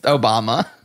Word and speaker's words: Obama: Is it Obama? Like, Ma? – It's spelled It's spelled just like Obama. It's just Obama.